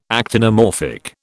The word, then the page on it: actinomorphic: British English